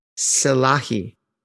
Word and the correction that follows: Silalahi: or Silalahi. Sorry, I mispronounced that. Jackson Silalahi. And they've done a version of Alive.